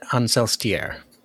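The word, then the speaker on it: Ancelstierre: And it's kind of the same for when she's in, and I cannot say it properly, I always say Ancelster. when she's in Ancelster, or however you say it. – The audiobook, Tim Curry said Ancelstier. – Very French sounding.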